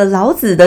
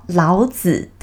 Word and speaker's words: 老子: 哇，千万大家不要念成老子的心情故事，好不好？我讲的这个老子的心情故事是，呃，著作这个《道德经》两千五百年前《道德经》的这个著作人，好，老子的心情故事。